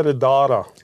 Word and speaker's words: Riddara: The it's called a Radara.